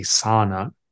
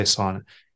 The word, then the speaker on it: sauna: You know, the thing I love most I found is a sauna, or it's actually properly pronounced a sauna, sauna, but I like to say sauna.